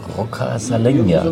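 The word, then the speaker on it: Roccascalegna: Wie spricht man das aus? Rocca Salegna? Also noch ein paar Wochen oder ein paar Tage, dann gehst du auch langsam als Italiener durch. Ja, genau so spricht man es aus.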